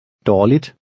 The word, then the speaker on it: dårligt: Dårligt. Dårligt. – Remember that the G is silent when it follows a vowel.